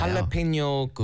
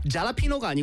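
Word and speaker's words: jalapeño: 스펠링이 소리는 H인데요. 스펠링이 네. J예요. – J입니다. 왜냐면 스페니시에서 그 J가 그렇죠. 흐 소리를 내잖아요. – 할라페뇨 그 페퍼도 페퍼 그렇죠. – 있잖아요. – 잘라피노가 아니고요. – 할라페뇨.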